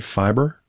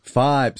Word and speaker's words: Fybe: Am I saying this right? Fiverr? – Fibe. – Sorry. – See, I believe. Yeah. Yes. F-Y-B-E. – F-Y-B-E. Yeah, you tell me how to pronounce that.